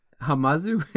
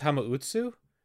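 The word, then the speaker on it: Hamauzu: I think it's Hamautsu. Because you got to do Hama as 2 syllables, and then Utsu should be 2 as well.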